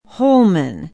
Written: holmen

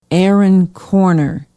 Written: Erin Corner

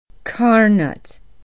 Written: carnot

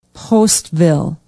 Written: postville

